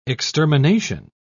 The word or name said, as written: extermination